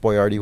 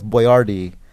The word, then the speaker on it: Boyardee: Like, in recent years, they've gone real hard on, like, the artisanal practices of the original Chef Boyardee, who was Chef Boyardee. – Mm. – It was spelled B-O-Y-A-R-D-I. – Yeah. – And then now they spell it B-O-Y-A-R-D-E-E because it's easier for us Americans to say.